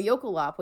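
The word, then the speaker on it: jökulhlaup: So a yokolop, which is spelled J-O-K-U-L-H-L-A-U-P. – Oh, that clarifies things. Because when you said this before, I was like, you're just making things up. – Yeah, yokolop is an Icelandic word.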